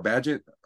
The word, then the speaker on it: Bagent: We don't know who's at quarterback for the Raiders, and we do know that it's what the rookie Tyson Bagent or Badgett. That's how you say it.